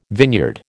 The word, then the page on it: vineyard: American English